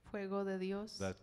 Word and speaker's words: Fuego de Dios: How do you say it? – Fuego de Dios. – That's it right there.